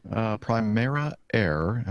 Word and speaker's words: Primera Air: Primera Air, am I pronouncing that correctly? – Yes.